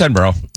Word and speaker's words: Edinburgh: That's Edinburgh. – Yeah. – That's how you... – Yep, that's how you say it.